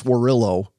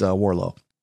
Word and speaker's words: Warlow: You don't pronounce Warillo, Warillo. It's Warlow. Anyway, look for John's Built to Sell.